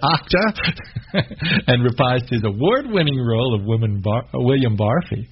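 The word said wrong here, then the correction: Barfée: Barfay. – Pronunciations aren't always my strong suit.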